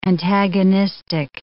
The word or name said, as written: antagonistic